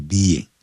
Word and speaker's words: being: 调侃应该这么说 teased 就是被取笑 threatened 然后被怎么样呢威胁 and 被怎么样呢 hit 被揍、啊、就等于其实它原来是讲 being teased being threatened and being hit 可是有太多的 being 所以它只要一个 being 就可以了这个 being、啊、要念成不要念成 being 你要先把 B-E 念出来 being 再加个 ing 的音、